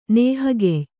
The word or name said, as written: ನೇಹಗೆಯ್